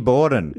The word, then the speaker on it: Borden: No, it rhymes with Fizzy Forden. – Oh, Lizzie. – Yep. – What's the second bit? Forden. Horden, Morden, Corden, Dorden, Borden. – Borden. – Lizzie Borden.